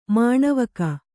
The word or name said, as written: ಮನವುಕ್ಕು